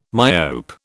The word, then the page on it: myope: British English